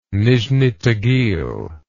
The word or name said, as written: nizhni tagil